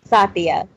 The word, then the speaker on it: Satya: Satya.